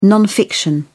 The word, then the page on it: non-fiction: British English